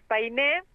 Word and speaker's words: Painé: Tenemos un Facebook que es Painé, así con acento en la E, y estamos en Instagram, Painé Cerveza Artesanal.